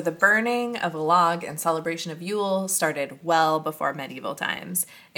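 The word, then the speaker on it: Jól: Oh, so J-O with the little K on top of it and the L, that's pronounced Yule.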